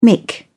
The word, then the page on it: mick: British English